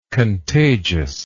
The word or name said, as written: contagiosity